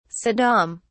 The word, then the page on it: saddam: British English